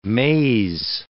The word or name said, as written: maze